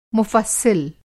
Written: मुफ़स्सिल

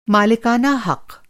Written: मालिकाना हक़

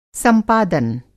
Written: संपादन

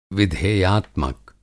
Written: विधेयात्मक

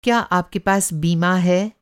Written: बीमा

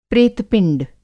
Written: प्रेतपिंड

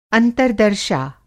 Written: अंतर्दर्शा

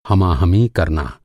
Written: हमाहमी

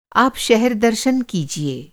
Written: दर्शन

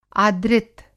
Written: आदृत

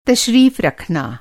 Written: तशरीफ़

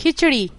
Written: खिंचड़ी

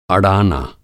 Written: अड़ाना

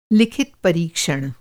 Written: लिखित

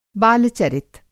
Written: बालचरित

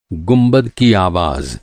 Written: गुंबद

